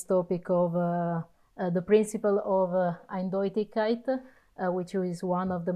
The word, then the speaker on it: Eindeutigkeit: One first topic is, I don't know if I'm going to pronounce it properly, but Eindeutigkeit. – Eindeutigkeit.